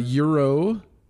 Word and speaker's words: gyro: So now in in the original book, there was a euro, or as uh, the kids around here pronounce it gyro, gyro.